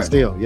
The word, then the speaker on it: Castile: Philo Castile, if I'm saying that right. Castile, yeah. Philando Castile, yeah.